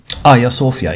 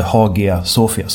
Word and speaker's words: Hagia Sofia: Aya Sofia, inte Hagia Sofia som det skrivs.